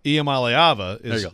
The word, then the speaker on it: Iamaliava: I want to say, I want to emphasize the second syllable. – You do. – Iamaliava is correct.